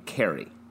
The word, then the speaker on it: Kerry: It's pronounced Carrie and Kerry. – Right. – It's yeah. Carrie. You're saying and Kerry. The same goddamn name twice. – No, no, no. There's a very distinct difference between the two pronunciations and the two characters.